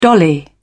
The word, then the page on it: dolly: British English